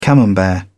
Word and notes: camembert: British English

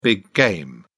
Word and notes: big game: British English

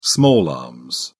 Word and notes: small arms: British English

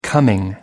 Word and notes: coming: American English

